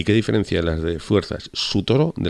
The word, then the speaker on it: Sutoro: Asirios cristianos sería la policía de los asirios cristianos, que son Sutoro, con S empieza la primera sílaba, S-U, Sutoro.